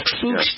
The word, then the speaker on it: Fuchs: F-U-C-H, it's pronounced F-O-O-K-S. Fuchs dystrophy.